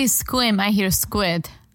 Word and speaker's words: Sequim: Squim is like S-E-Q-U. It's a very, Like Sequim. – Yeah. Don't say it like it's spelled. Say it like squid. But with an M.